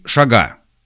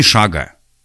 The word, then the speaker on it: шага: Сделать три шага. Не шага.